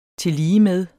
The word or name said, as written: tilligemed